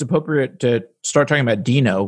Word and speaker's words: Deno: There is some confusion about the pronunciation, but on the TSConf 2019, Ryan Dahl clarified that the official name was pronounced Deno.